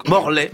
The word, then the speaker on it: Morlaix: Mais oui, les policiers de Morlaix ont reçu une carte postale les remerciant d'une... Morlaix, me dit-on dans l'oreille. – Attention, évidemment, on ne prononce pas de X, c'est autant pour moi.